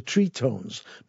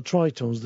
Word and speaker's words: Trí-Tones: They've called themselves the Tritones, not the Tritones. The Tritones are another group entirely. I think they're like a rockabilly band. Tritones is spelt T-R-I Fada. It's got a little accent on it.